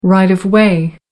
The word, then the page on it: right of way: American English